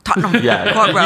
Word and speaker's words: Tottenham Court Road: Did I say that right? – Yeah. – Good enough. Say it for me. – Tottenham Court Road. – Tottenham Court Road. – You probably said it better than us. – You pronounced all the letters.